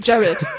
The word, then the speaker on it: Jared: Dann bekam sie jedenfalls ihr erstes Kind, Gerard. Nee, ich muss ja Englisch aussprechen. Jared, sorry.